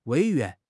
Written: 委员

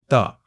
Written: 的

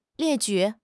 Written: 列举

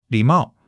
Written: li3 mao4

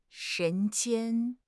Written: 人间

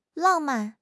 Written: lang4 man4

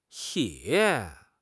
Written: ye3

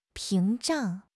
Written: 屏障

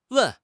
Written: wen4

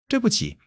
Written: 对不起

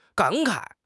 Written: 感慨

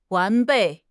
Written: 完备

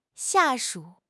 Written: 下属

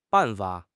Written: ban4 fa3

